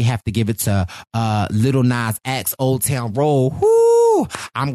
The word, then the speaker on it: Lil Nas X: Yes, Lil Nas X.